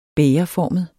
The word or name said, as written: bægerformet